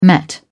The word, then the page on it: met: British English